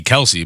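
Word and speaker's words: Kelce: I think, well, we say Kelsey, but I think on the podcast he said their last name is actually Kels.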